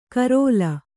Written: ಕರೋಲ

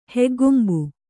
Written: ಹೆಗ್ಗೊಂಬು

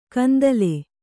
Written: ಕೆಂದಲೆ

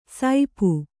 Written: ಸೈಪು

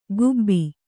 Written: ಖೂಬಿ

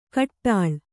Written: ಘೋಟಾಳಿ

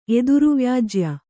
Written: ಎದುರುವ್ಯಾಜ್ಯ

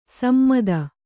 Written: ಸಮ್ಮತ

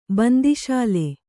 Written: ಬಂದಿಶಾಲೆ

